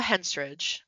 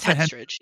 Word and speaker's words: Henstridge: Natasha Henstridge, is that how you say her last name? I can never know how to say her last name. Henstridge. – Natasha Henstridge. Henstridge.